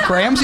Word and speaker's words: Graham: There's a distinction between the two Grahams you just said? – Well, yeah, G-R-A-H-A-M has got two syllables.